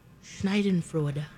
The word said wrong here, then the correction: Schadenfreude: Schadenfreude. – You weren't even close.